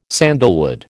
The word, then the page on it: sandalwood: American English